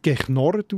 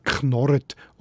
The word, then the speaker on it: knorret: So gibt es zum Wort «knorren» ein Adjektiv mit der Vorsilbe «g», aber das wird dann nicht «geknorret» ausgesprochen, sondern eben «knorret» oder «knorrechtig» oder auch «knurrecht».